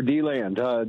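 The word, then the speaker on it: DeLand: Yes, sir, D-Land.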